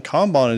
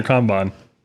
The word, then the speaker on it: Kanban: Kanban or Kanban? – I say Kanban. But it's spelled K-A-N-B-A-N for anybody out there.